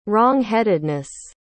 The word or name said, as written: wrongheadedness